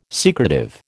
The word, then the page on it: secretive: American English